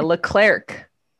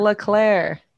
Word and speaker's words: Leclerc: She's always doing a hard C, a Leclerc. – No, it's like you're eating. – Like it's a CK, and I'm like, it's Leclerc. They even make the signs in Monaco that say it like that.